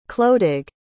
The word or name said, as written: clodagh